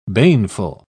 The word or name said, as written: baneful